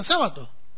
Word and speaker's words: Sábato: Pero en Italia se pronuncia, se escribe así, pero se pronuncia sábado, como esdrújula.